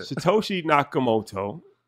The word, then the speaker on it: Satoshi Nakamoto: Satoshi Nakamoto. Did I say that correctly? – That was good, bro.